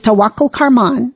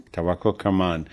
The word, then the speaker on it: Tawakkol Karman: How would you pronounce it correctly? – Yeah, it's Tawakkol Karman. – Tawakkol Karman.